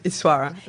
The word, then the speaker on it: Yswara: Am I correct in the pronunciation? – Well, yeah, Yswara.